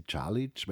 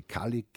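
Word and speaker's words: Čalić: Sitzt da zeitlang hinter seinem Computer, grabbelt herum, ja, und erklärt mir dann, es gibt in München eine Universitätsprofessorin, die Marie-Janine, ich nenne sie Calic, weil ihr Vater ist Kroate, ich glaube in Deutschland wird sie Kalik ausgesprochen mittlerweile, die über diese Zeit geforscht hat und geschrieben hat und eine besonders lebendige Art des Erzählens hat.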